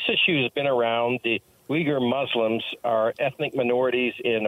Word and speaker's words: Uyghur: So the United States is sanctioning Chinese officials over the Uyghur, am I pronouncing that right, human rights abuses? – Yes, Uyghur. Yes, this is interesting.